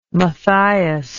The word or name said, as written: Matthias